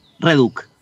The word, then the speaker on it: Reduk: ¿Lo dije bien, es Reduc o Reduc? – Reduc. – Lo, había dicho bien entonces desde el comienzo.